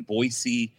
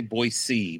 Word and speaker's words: Boise: Like and one of the best compliments I ever got was in Boise, Idaho. And I have to say Boise because they get mad if you don't pronounce the S in the hard way.